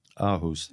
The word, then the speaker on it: Aarhus: We ran on the national.ae, we ran a very interesting Bloomberg piece about the Danish city of Aarhus. I hope I'm pronouncing it correctly. Apologize if I've mangled it. – Aarhus.